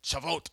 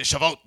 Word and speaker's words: Shavuot: Did I pronounce that right, Will? Shavuot, okay. Everybody say Shavuot the way that Will says it.